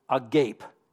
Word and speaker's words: agape: It's spelled just like the word agape, as in your jaw drops and you're staring at something, but you got to be cool and pronounce it Greekish. Agape, that's the word.